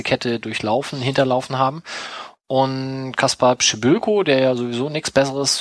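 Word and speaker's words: Kacper Przybyłko: Ich applaudiere dir gerade für das korrekte Aussprechen dieses Namens. – Kaspar Pschibylko. Das hast du bei Rainer geübt, also Rainer Wolf? – Na, Kaspar Pschibylko, der hat doch letztes Jahr mit Bielefeld schon 2 Tore gegeben.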